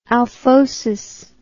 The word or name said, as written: alphosis